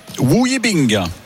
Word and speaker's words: Wu Yibing: Espagnol sera opposé à un Chinois, Wu Yibing.